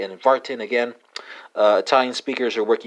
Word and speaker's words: warten: Dutch infinitive is spelled W-A-C-H-T-E-N. German infinitive is spelled W-A-R-T-E-N. Spelled respectively, Wachte and Varten. Or pronounced respectively, Wachte and Varten.